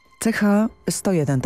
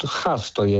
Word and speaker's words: Ch-101: CH-101, tak? Właściwie wymawiam nazwę tego pocisku? – Tak, ja sobie pozwalam na skrót H-101, mhm.